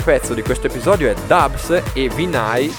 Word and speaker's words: DVBBS: Si pronuncia Dubs.